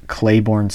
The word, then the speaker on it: Claiborne: So uh, it's my Instagram is uh, s underscore Claiborne Smith, and Claiborne is spelled C L A I B O R N E.